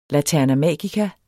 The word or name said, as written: laterna magica